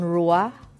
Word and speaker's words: ruach: Ano ni siya mag-pronounce, no? Sa mga abyan aton rua, rua, R U A C H, ruach, no? Or rua.